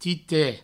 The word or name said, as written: tite